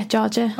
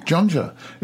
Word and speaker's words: Jorja: Is it pronounced Georgia? – Yeah, Georgia. – Ah, why? How are you pronouncing it? – Jonja. It's J-O-N-J-A. – No, J-O-R-J-A. – Oh, it's an R, is it? – It's an R.